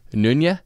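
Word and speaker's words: Nøgne: So it's written out Nogne. – Yeah. – Nogne. – Come on, I know my home country's pronunciation. No, I don't really. I just, like, I pulled up a YouTube clip. – Googled it? – To hear the, like, head brewer or whatever give his pronunciation. And okay. I think it's Nunya, but.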